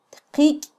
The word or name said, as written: tqiky